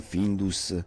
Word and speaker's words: Findus: ma anche un messaggio subliminale di aiuto tipo Find Us, che significa trovateci? Eh sì, perché Findus, noi lo leggiamo Findus, e in America si legge Find Us.